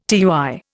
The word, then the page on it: tui: Indian